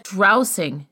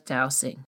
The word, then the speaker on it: dowsing: I said drowsing instead of dowsing. The latter is the correct term.